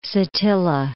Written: Satilla

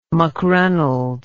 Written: McReynolds

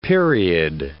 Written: period

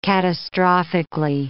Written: catastrophic

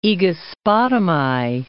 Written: Aegospotami

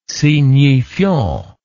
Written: signifiant

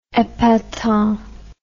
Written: epatant

